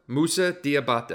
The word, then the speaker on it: Musa Diabate: How do I pronounce Moose's name here? – Musa Diabate.